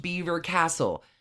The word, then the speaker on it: Belvoir: If you are reading the word, you're never going to know that it's pronounced Beaver Castle. And the spelling of this castle is B-E-L-V-O-I-R.